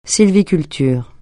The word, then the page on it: sylviculture: francais